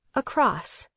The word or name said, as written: across